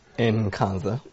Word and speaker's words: Nkansah: Nkansah.